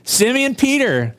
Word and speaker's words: Simeon: Did I say Simon Peter? Because it says Simeon Peter. Simeon Peter. And Peter is not, you know, as we mentioned last week, not often referred to in the Scripture as Simeon. He was called Simon, but that's the Greek variation of his name. Simeon is the Semitic Hebrew way to properly say his name.